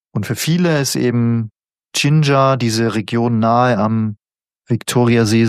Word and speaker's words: Jinja: Jinjar schreibt man nicht wie Ingwer, sondern J-I-N-J-A. – Ah, natürlich, ja genau, das könnte... genau. – Fällt mir gerade auf, dass man es genauso ausspricht wie Jinjar.